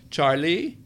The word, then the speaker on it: Charlie: "Charlie," she said, emphasizing the second syllable, "I am so happy.